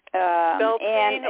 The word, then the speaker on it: Beltane: It's called Beltane, right? Is that right? Journey. – Beltane, in Irish.